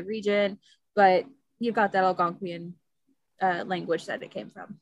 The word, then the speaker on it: Algonquian: It's supposed to be based off, I have no idea how to say this, Algonquian.